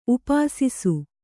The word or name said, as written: ಉಪಾಸಿಸು